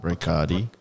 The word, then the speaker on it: Brocarde: I might be pronouncing this wrong. Brocardi or Brocard? B-R-O-C-A-R-D-E. – No. – Brocard. – Brocardi. – Brocardi. – Like the drink. – Yeah. – Bacardi. But bro.